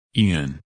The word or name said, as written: Ian